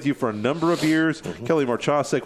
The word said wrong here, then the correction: Mahacek: which I may not be saying her name correctly. – Mahacek. – But I say that again. – Mahacek. – Mahacek. Okay, yeah. It's a couple of silent vowels in there and syllables.